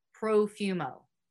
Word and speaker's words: Profumo: John Porfumo, I think that's how you say it. Porfumo? Porfumo. We're going to go with Profumo.